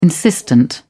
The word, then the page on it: insistent: British English